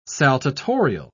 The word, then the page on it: saltatorial: British English